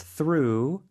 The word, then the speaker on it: through: the O-U is a different sound, and then there's through, where it's just ooh.